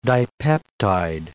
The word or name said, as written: dipeptide